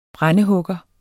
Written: brændehugger

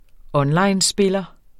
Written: onlinespiller

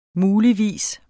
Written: muligvis

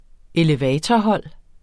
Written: elevatorhold

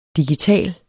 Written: digital

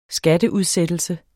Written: skatteudsættelse

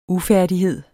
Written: ufærdighed

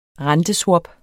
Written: renteswap

